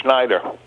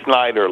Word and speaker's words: Schneider: Schneider. – Uh-huh. – Indeed, I'm giving you the correct pronunciation. Most people say Snyder like Duke Snyder.